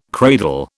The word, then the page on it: cradle: British English